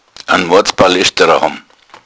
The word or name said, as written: balesterer